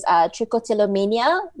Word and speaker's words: trichotillomania: So I think the most common that people are hearing nowadays is trichotillomania. I'm probably botching how I'm pronouncing it. – No, that sounds correct, actually.